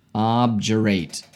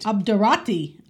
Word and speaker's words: obdurate: Obdurate. O-B-D-U-R-A-T-E. Obdurate. – Obdurati.